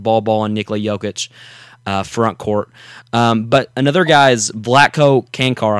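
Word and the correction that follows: Čančar: I think that I pronounced that right. – It's Chanchar. Okay. It's very weird of a name. It's not everyone on the face of the earth has gotten that name wrong.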